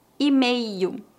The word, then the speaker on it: e-mail: Lembre-se que no Brasil nós não pronunciamos o L em final de palavras. Em final de palavras, o L é pronunciado como U. E-mail, Gmail, Hotmail.